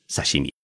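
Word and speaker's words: さしみ: 记住，生鱼片在日语当中念作是沙西米。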